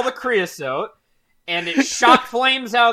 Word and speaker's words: Creosote: Yeah, Creosote is how you say it.